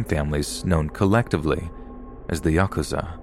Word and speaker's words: Yakuza: The rules of the game are fairly complex, but the worst possible three cards to draw are 8, 9, and 3, pronounced Yakuza in Japanese.